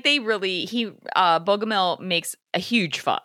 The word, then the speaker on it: Bogomil: Earlier in our conversation, you brought up Lieutenant Bogomil. Am I saying that right? – Bogomil, yeah.